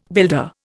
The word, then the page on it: builder: Indian